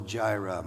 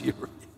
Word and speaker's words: Yireh: You know, the song says Jehovah Jireh, my provider. Yireh doesn't work with that. It doesn't, messes the rhyme up. But it's more properly pronounced Yireh.